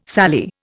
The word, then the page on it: Sally: Indian